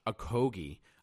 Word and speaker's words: Okogie: They end up taking Josh Okogie. Now, again, that is how you pronounce his name. It is Josh Okogie.